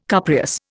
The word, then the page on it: cupreous: Indian